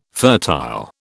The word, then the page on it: fertile: British English